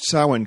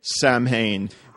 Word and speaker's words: Samhain: I like that you pronounced Samhain correctly. – I looked that one up. – Because it's spelled Samhain, and I've heard people say Samhain, and then I point at them and laugh.